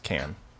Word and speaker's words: Cannes: It's pronounced Can.